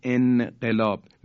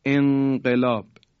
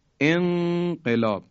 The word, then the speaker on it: انقلاب: یا در انقلاب، نون در قاف، کسی نمیگه انقلاب، میگیم انقلاب انقلاب.